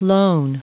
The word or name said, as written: lone